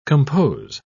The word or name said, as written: compose